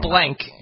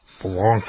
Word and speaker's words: Blanc: Sauvignon's not the problem there. It's Sauvignon. Not blank. It's Blanc. – Blanc.